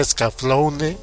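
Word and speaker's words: Escaflowne: Así se pronuncia, Scaflaune.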